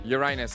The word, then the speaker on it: Uranus: Could be UFO or everybody's favourite planet, Uranus. – Yeah. Say it properly. – Uranus.